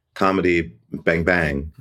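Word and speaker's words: Comedy Bang! Bang!: Yeah, that was, I just stopped doing comedy Bang Bang. – Right. – Oh, wait, so the emphasis is on the second Bang.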